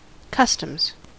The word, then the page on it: customs: American English